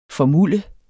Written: formulde

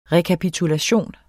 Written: REKAPITULATION

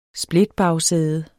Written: splitbagsæde

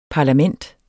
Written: parlament

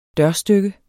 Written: dørstykke

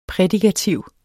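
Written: prædikativ

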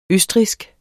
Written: østrigsk

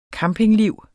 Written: campingliv

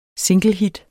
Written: singlehit

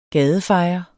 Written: gadefejer

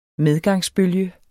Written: medgangsbølge

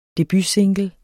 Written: debutsingle

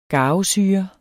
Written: garvesyre

